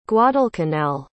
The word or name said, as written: guadalcanal